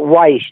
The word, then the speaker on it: Weiss: Also, spell it Weiss, W-E-I-S-S, when you send my check for my Thank you so much for your time and sharing these memories.